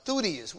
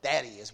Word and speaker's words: Thaddeus: Thutis. We normally pronounce that Thaddeus, but it's Thutis.